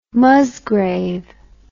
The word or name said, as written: Musgrave